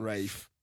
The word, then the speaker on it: Ralph: And you'll get a great performance out of Ralph. – Rafe. – Ralph. – Rafe. – How's his name spelled? – R-A-L-P. H. – Ralph. – The old English way of pronunciation is Rafe.